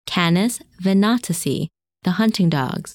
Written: Canes Venatici

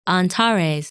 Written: Antares

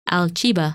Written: Alchiba

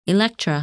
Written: Electra

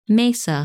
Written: Meissa

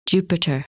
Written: Jupiter

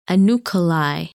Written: Unukalhai

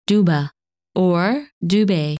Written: Dubhe

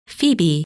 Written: Phoebe

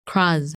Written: Kraz

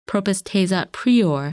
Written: Propus Tejat Prior

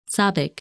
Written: Sabik

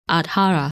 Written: Adhara

